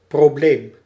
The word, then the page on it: problem: American English; [ˈpɹɑb.ləm]